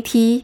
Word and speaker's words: te: Instead of saying tea, they pronounce it as te.